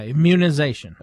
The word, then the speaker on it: immunization: Where do we put the emphasis on that word, Beaver? We're talkers. Do we put the emphasis on the M in immunization, or do we put it on the mun, immunization? – I do the mun. – Do you? – Yeah, immunization.